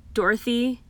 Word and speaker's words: Dorothea: So, with that being said, today we're going to be talking about, so, I know it's pronounced Dorothy, but it's spelled Dorothea, you know, like D-O-R-O-T-H-E-A, Dorothea.